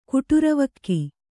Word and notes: ಕುಟುರವಕ್ಕಿ: kuṭuravakki

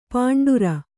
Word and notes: ಪಾಂಡುರ: pāṇḍura

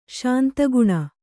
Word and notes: ಶಾಂತಗುಣ: śanta guṇa

